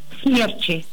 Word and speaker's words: смерчей: Да, ну вот тут, по-моему, все было очень просто, да? Просто, но со вкусом. Конечно, смерчи. – Ура. – И никак иначе. Это ударение твердое. Оно там, как говорится, забронировало за собой место и никуда не двигается.